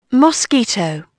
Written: most

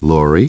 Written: Laurie

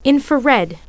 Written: infrared

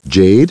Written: Jade

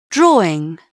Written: drawing

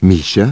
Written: Misha

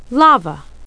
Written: lava

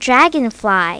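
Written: dragonfly

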